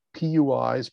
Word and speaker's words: PUI: We've clarified the rules for faculty at PUIs, predominantly undergraduate institutions. We pronounce that PUI.